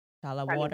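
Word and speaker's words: Chale Wote: C-H-A-L-A. – Okay. Charlie Watte. – Charlie Watte. Yeah. – What is it? W-A? – C-H-A-L-E. And then W-O-T-E.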